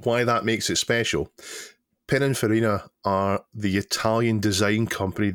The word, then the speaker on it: Pininfarina: So this particular car is a Pininfarina conversion. Am I pronouncing that right? Chris? I think so.